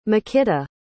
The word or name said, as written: mykyta